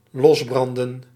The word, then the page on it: losbranden: los·bran·den